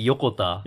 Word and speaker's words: Yokota: Yeah, it's supposed to be Yokota.